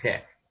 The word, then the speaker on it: TeX: Well, it turned out that this fellow Donald Knuth, who had invented and wrote for Unix mainframes, the Tech typesetting language, T-E-X, but it's pronounced Tech after the Greek.